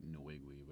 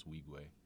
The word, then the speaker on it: Nwigwe: I mean, everyone mispronounces his name. They say like Nwigwe, but it's Wigwe because he's Nigerian.